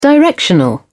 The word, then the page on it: directional: British English